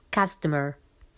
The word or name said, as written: customer